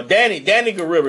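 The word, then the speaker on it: Danai Gurira: Deanna Guerrero. Oh, Danny Guerrero is how you pronounce that shit.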